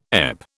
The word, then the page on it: EP: British English